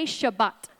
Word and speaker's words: Shabbat: Say Shabbat.